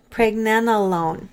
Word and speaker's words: pregnenolone: So those other um, hormones you mentioned earlier, DHEA, and is it pregnant? How do you say that? – Pregnenolone.